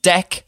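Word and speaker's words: dek: Pronounced deck, I'm told. The Dozenal Society of America tells me it's pronounced deck.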